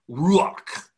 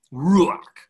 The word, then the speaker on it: ruach: a word for Spirit that is pronounced something like ruach, ruach.